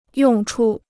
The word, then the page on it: 用处: yòng chu